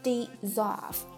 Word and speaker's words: dissolve: 比如说解决这个问题叫做 solve the problem，那前面加上了 D-I-S， dissolve，发音也有一些改变啊，这个词读作 z。